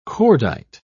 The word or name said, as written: cordite